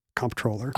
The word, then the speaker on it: comptroller: I think it's supposed to look like comptroller, you know, due to its French origin, but be pronounced controller. But because it looks like comptroller, it's okay to say comptroller.